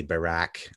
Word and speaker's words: Barak: You know, Ehud Barak was also, I know I pronounced that with a T, but their T and K is kind of similar, but we'd say Barak, I guess, kind of like, sounds like Barack Obama, but Ehud Barak.